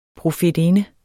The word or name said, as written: PROFETINDERNE